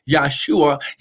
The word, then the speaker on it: Yeshua: Listen, he was not given the name Yeshua, amen, because it could not have been Jesus, because there's no J in the Hebrew language. That's just uh, uh, that's just for your information, right there. The, his his name would have been Yeshua, pronounced Yeshua, Yeshua Hamashiach.